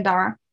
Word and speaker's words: Darragh: So I'm on Amazon as Megan Dara. And then my surname is spelled D-A-R-R-A-G-H. Yep. And Megan is just M-E-G-A-N. Then I'm on Twitter, Megan Dara 3.